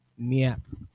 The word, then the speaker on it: NYAP: And if you see us, it says NYAP, like a nap with a silent Y.